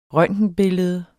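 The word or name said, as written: røntgenbillede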